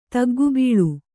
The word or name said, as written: ತಗ್ಗುಬೀಳು